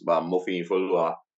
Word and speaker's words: Mofiyinfoluwa: My full name is Mofiyinfoluwa. Yeah, but it's more for sure that I'm sure you have a hard time to pronounce that. It's six syllables.